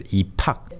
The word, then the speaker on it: 입학: So 입 and 학, you pronounce it as 입학, E and 팍.